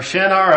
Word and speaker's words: Shinar: It says, in verse 2, it says, "And they found a plain in the land of none other but Shinar." or Shinar, or however you want to pronounce it.